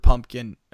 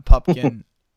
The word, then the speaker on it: Pupkin: It was very different, and I think it was relatable, because you know, obviously it's taken to an nth degree, but who hasn't felt like Rupert Pumpkin or a Pupkin? Which is funny. They say, as a joke, they say every single character says his name wrong every single time.